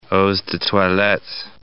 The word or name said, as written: eaux de toilettes